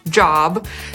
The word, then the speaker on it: Job: Na tej samej zasadzie działa słowo, które znacie jako praca, czyli job, zapisywane małą literą, właśnie tak się je wymawia. Natomiast jeśli zapiszemy je wielką literą, czytamy Job i jest to biblijne imię, Hiob.